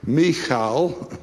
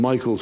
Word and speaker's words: Michal: His own wife, whose name is pronounced Michal, Michal, sounds better than Michael somehow, Michal, she says, "You're like any one of the vulgar fellows."